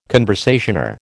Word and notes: conversazione: American English

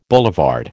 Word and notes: boulevard: American English

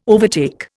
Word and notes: overtake: Indian

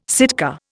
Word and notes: Sitka: Indian

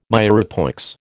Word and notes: mirepoix: American English

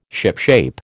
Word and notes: shipshape: American English

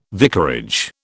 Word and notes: vicarage: British English

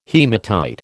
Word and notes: haematite: American English

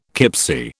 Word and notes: kipsie: British English

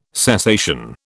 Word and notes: cessation: British English

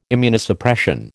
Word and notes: Immunosuppression: American English

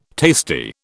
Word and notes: tasty: British English

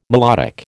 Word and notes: melodic: American English

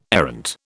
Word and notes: errant: British English

